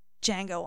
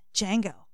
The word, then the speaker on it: Django: Is it spelled like Django Reinhardt? D-J-N-G-O? – J-A-N-G-O. – Oh, it's spelled phonetically. – Django. – Django. Like Django Unchained. But that was also with a D, so.